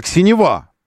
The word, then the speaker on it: Нива: В общем, это та самая и Нива. Мы для начала должны запомнить, как это правильно произносить. Нива. Запомните, это же просто взять. Синева и Нева.